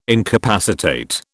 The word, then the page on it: incapacitate: British English